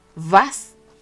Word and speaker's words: was: Ejemplo, vi, para preguntar, vi día, vi, aunque se escribe W-I-E, se dice vi. Eh, también para preguntar qué, vas, aunque se escribe eh, W-A-S, suena V, porque la letra W se pronuncia en alemán como V.